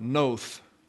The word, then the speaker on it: Nod: In Genesis 4:16, so Cain went out from the presence of the Lord and lived in the land of Noth. That's the Hebrew pronunciation. It's Nod here. But you have a TH on the D, and the O is long, so it's the land of Noth, which is a land of wandering.